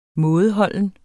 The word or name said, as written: mådeholden